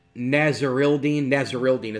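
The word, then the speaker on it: Nasirildeen: Nasrildeen is how you pronounce that.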